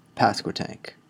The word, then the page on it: Pasquotank River: English; North Carolina